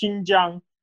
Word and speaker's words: Xinjiang: X, yeah, the X in Chi- Chinese pinyin system is pronounced like sh in English. So X I N is actually Xin, pronounced Xin, so it's Xinjiang, like like Xi Jinping, right? Oh, okay. X sounds like a sh.